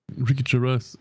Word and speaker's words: Ricky Gervais: Jervis, Gervais, Gervais, Ricky Gervais.